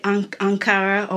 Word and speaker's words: Ankara: Am I saying it correctly, Ankara, or is it Ankara? – People have pronounced it different ways with an accent. My friend has just always told me to say it Ankara or Ankara.